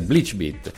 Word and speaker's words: BleachBit: La pronuncia è all'inglese, Bleachbit.